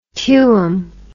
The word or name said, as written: tuum